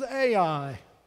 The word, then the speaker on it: Ai: Matter of fact, the city is so tiny, it had a tiny little name, and you know how to spell it because the way you pronounce it is how you spell it. The name of the city was Ai. And here's how you spell it Ai.